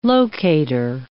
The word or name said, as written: locater